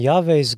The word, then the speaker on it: Yahweh: I mean, when you read it in the Passion, it looks as Yahweh, but there's no W in Hebrew, so it's pronounced with a V.